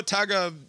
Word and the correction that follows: Tagovailoa: now, Tagovailoa. – That is, yeah, that's harder. Antetokounmpo is very straightforward.